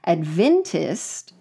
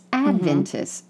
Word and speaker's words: Adventist: It's not Adventist. It's Adventist. Mm-hmm.